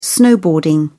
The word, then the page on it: snowboarding: British English